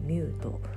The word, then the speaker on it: MUUTE: MUUTE と書いてミュート